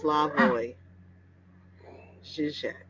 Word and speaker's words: Slavoj Žižek: Really Cool says it's pronounced Slavoj Žižek.